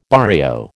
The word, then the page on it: barrio: American English